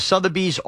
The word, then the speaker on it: Sotheby's: Sotheby's, I don't know how you pronounce Sotheby's.